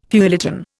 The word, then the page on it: feuilleton: Indian